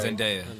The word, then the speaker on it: Zendaya: Zendaya, yeah.